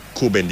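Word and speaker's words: Rúben: เจ้าตัวเนี่ยออกเสียงว่าเป็นคูเบนดิอาสนะพี่น้องตัว R ของประเทศโปรตุเกสเนี่ยออกเสียงเป็นตัว K นะฮะ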